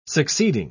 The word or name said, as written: succeeding